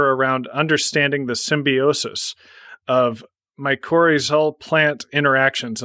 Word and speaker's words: mycorrhizal: That association is called mycorrhizal. – Mycorrhizal. – So, yeah, and if we breaking that word down into word roots, mycor is fungus, rhizal is root.